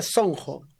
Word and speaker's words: Sonco: Bueno, más que nada, Sonco, que se pronuncia Sonco, es por el tema corazón, que significa, es un dialecto quechua.